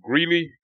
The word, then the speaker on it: Greeley: HBU restarts with a full slate in FCS football on September the 13th on the road in Greeley, Colorado. I hope I pronounced that correctly. – That's right. Yep.